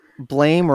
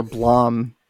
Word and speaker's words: Blame: Blame or Blom, people pronounce it differently.